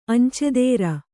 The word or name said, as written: ಅಂಚೆದಾರ